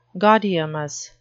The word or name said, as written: gaudeamus